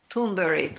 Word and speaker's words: Thunberg: Her real name is pronounced Thunberg. The way that you spell it isn't the way that it's said.